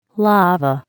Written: lava

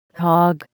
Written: hog

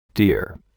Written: deer

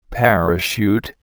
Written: parachute

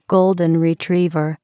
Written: golden retriever

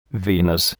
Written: Venus

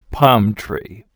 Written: palm tree

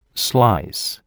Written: slice

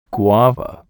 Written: guava

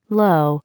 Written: low

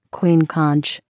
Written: queen conch

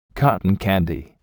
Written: cotton candy

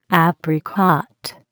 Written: apricot

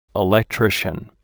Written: electrician

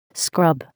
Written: scrub

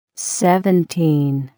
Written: Seventeen

